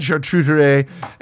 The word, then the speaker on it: charcuterie: Like, so you just have, like, a charcuterie? – Mm-hmm. – Is that the proper pronunciation? – Yeah. – Okay, you've got charcuterie and eggs, and, oh, my God, you've got, like, duck fat, butter.